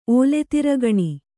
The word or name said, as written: ಓಲೆತಿರಗಣಿ